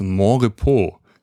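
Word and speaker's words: Monrepos: Ich hatte mich kurz gefragt, wie es ausgesprochen wird, aber ich hatte so die Vermutung, dass es irgendwie französisch ausgesprochen wird, ne? – Ja, wird es auch. – Ja, genau, Mont-Repos. Deswegen Montrepos, nicht Montrepos.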